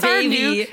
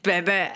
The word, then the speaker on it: Bebe: It's not baby, it's bebe. – How do you spell that? B-E-B-E with an apostrophe on the last E? – Yeah, probably, I guess.